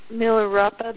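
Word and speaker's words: Milarepa: I didn't know Milropa, the saint. – Oh, okay. – Am I saying it right? – Mm-hmm.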